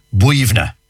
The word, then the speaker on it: Boyunagh: Like, for example, I know one, for example, one cemetery near Glenamedy, and it's spelled B-O-Y-U-N-A-G-H. B-O-Y. So you'd be saying Boyuna. No, yeah. Not a bit of it. B-O-Y-U-N-A-G-H is pronounced Buivna.